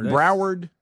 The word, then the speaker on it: Broward: Is it Broward? – Is that how you say it? – Okay. Yeah.